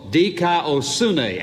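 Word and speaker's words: dikaiosune (δικαιοσύνη): The Greek word for justify is a word which sounds like this, dikaio, which is spelled D-I-K-A-I-O-O. The word for righteousness sounds just like it almost, dikaiosune, and the only difference is the S-U-N-E at the end of the second word.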